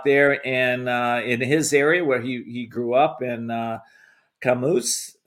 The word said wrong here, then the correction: Casamance: Senegal. I hope I'm saying that right. You'll correct me. – Casamance, Senegal.